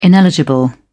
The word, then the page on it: ineligible: British English